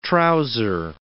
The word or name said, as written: trouser